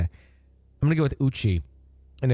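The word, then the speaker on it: Uche: Uchi. Uchi. You shy? – It's you? I thought it was O. – No, you.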